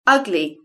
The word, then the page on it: ugly: British English